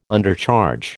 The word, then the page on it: Undercharge: American English